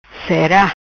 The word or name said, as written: Cera